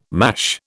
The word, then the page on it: mash: British English